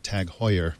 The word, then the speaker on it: Tag Heuer: And congratulations, Sean, on your pronunciation of Tag Heuer. Very well done.